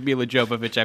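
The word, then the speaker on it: Milla Jovovich: I watched that movie so I can look at Mika Lovinovich's strap fall off. – Oh, yeah, absolutely. – Which character That's the best pronunciation of Mila Jovovich I've ever heard, by the way.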